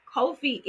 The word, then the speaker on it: Ko-fi: However, Ko-fi is actually how you pronounce that.